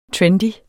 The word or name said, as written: trendy